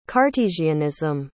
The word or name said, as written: cartesianism